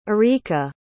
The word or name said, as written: arika